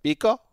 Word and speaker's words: Peko: During an event in London to promote the NFL's international series this season, Bengals defensive tackle Domata Peko. Is that how you pronounce it? Pico? – Dead on.